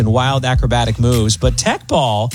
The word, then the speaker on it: Teqball: T-E-Q-B-A-L-L, pronounced Tech Ball.